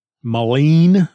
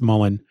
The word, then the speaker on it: Mullein: And I'm fairly certain it's pronounced Mullen. It's spelled M-U-L-L-E-I-N. Mullen. I think it's Mullen.